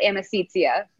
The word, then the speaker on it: Amicizia: I'm pretty sure it's pronounced Amicizia. Their chalkboard inside says it means friendship in Italian.